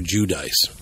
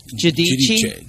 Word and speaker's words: Giudice: Because most people call her Giudice. – Ugh. I don't know. – Giudice, okay? Giudice. – And the true pronunciation is Giudice. – Giudice.